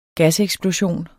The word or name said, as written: gaseksplosion